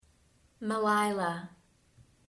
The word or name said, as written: Melailah